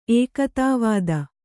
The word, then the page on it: ಏಕತಾವಾದ: ēkatāvāda